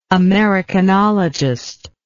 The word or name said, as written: americanologist